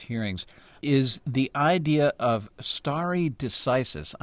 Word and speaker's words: stare decisis: I'm not even sure I'm pronouncing this correctly, not being a lawyer or legal scholar. I'm asking you to explain this for us. – You're pronouncing it beautifully, and narrowly stare decisis simply means you don't go around upsetting prior court rulings willy-nilly.